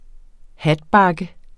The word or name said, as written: hatbakke